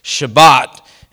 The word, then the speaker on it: Shabbat: In Hebrew, it's pronounced Shabbat, and it's where we get the word Sabbath.